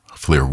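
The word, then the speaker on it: Llŷr: Flir Williams, the pianist. His name is spelled L-L-Y-R. Flir Williams.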